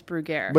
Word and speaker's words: Bruggere: This is everybody pronounces my last name Brugger. – Brugger. – Because they pronounce the French way, and I correct them, and I say, no, this is America. – Brugger. – And it's Brugger.